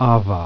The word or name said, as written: beta